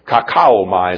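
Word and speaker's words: kauchaomai: Kakaomai is the way you pronounce it.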